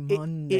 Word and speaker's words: Monday: Well, why is it M-O-N? – Mon. It should be Monday. – It literally, yeah, M-U-N. It rhymes with Sunday, which is S-U-N.